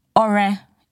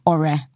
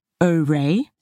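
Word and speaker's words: Ọrẹ: And there's a dot under the O and a dot under the E to show that it is Ore and not Ore. And there's an accent on the U and the A to show that it goes Ore Oluwa, goes up and down.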